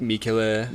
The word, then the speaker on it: Michele: Is it Michele? – Oh, yeah, like Michele.